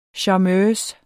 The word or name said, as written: charmeuse